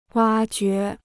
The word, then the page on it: 挖掘: wā jué